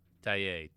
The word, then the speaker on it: Taillet: Chaton Taillé. T-A-I-L-L-E-T.